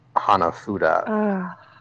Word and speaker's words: hanafuda: It's pronounced Hanafuda. Hanafuda.